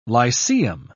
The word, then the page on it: lyceum: British English